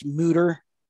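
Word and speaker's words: Mütter: Actually, I believe it's pronounced Mutter. But yeah, it is.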